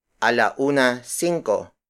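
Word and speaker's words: ala-una singko: For example, if the time is 1:05, we say a la una cinco. A la una cinco. A la una cinco. A la una cinco is spelled as A-L-A hyphen U-N-A space S-I-N-G-K-O. In Spanish,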